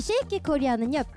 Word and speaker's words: 쉐이킷 코리아: 쉐이키 코리아. 여러분, 발음 실수하시면 안 돼요. 쉐이킷 코리아입니다. 네, 이 쉐이킷 코리아는요, 퓨전 국악 밴드 두 팀이 각자 연주를 하고 같이 합동 무대까지 여러분들께 들려드리는 네, 방송입니다.